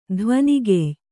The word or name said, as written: ಧ್ವನಿಗೆಯ್